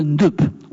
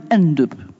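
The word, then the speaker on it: Ndeup: Et ça s'appelle, d'un nom imprononçable, le Ndup. On dit aussi quelquefois le Ndup.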